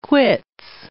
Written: quits

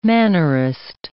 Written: mannerist

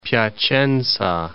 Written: Piacenza

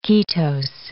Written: ketose

